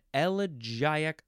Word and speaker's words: elegiac: E-L-E-G-Y. Yes, I said it right. Number one, a poem in elegiac couplets. Elegiac, that's how you say it. Now, some people might want to say elegiac. That's fine, too.